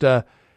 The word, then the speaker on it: Haribo: And is it pronounced Haribo? – Mm-hmm.